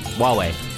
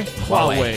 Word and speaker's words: Huawei: Huawei. – Huawei? – Huawei. – H-U-A-W-E-I.